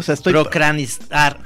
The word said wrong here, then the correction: procrastinar: Procranistar. No. Procrastinar, perdón.